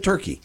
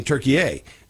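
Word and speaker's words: Türkiye: All the news media outlets, everybody's always said turkey. Yep. Now we had the World Cup here, and they started saying Turkey A, which is, I guess, how they pronounce it there.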